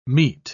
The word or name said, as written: meet